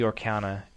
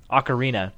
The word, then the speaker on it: ocarina: It's not the Orkana. It's the Ocarina.